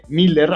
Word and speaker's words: Millar: Que es un escritor conocido como Mark Miller o Millar. De hecho, hay quien dice que se dice Miller, aunque se escribe Millar, y usted pronúncielo como quiera, le van a entender.